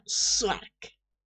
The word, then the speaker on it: Szwarc: ¿Qué clase de apellido se escribe SZWARC? O sea, Swark.